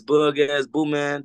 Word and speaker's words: Bug Azz Booman: It's Bug Azz Booman.